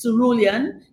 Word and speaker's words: cerulean: So when people ask me why, they have all kinds of pronunciations of it. And I say it is cerulean.